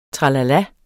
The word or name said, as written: tralala